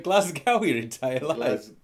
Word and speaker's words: Glasgow: Don't pronounce the W in Glasgow. – Glasgow, the Dutch.